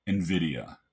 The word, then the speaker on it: Nvidia: There was only one name on Wall Street's lips last week, chipmaker Nvidia. And yes, I did look up. Apparently, that is the way it's pronounced.